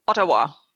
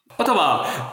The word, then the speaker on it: Ottawa: Stell dir vor, die kommen direkt zu dir nach Ottawa, wird das so ausgesprochen? – Ottawa. – Ottawa.